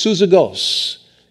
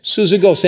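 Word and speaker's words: Syzygos: It's pronounced something like this, Suzygos. Suzygos, and it was a name in ancient Greece.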